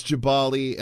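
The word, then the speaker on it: Gbahali: But I believe it's Jabali, and I'm going to stand by that. – It's G-B-A-H-A-L-I.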